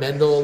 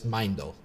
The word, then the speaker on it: Meindl: Most people want to have an extra syllable. – Mindel. – Yeah, I've gotten Mendel a lot, Mindel even, but it's Mindel.